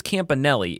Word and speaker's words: Campanile: Anthony, apparently it's Campanelli. It's not spelled like Campanelli, but it's pronounced Campanelli.